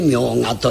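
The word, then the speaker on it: 茗荷: 名鬼の尾と書いて明賀と読ませるということを言います。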